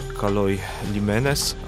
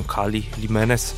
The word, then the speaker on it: Kaloi Limenes: To miejsca, które też znajdziemy na mapie pod tą samą nazwą grecką, która funkcjonowała 2000 lat temu, czyli Kaloi Limenes, albo jak to współcześni Grecy wymawiają, Kali Limenes, czyli te dobre porty, albo też można przetłumaczyć, tak spotkałem takie tłumaczenie, piękne przystanie.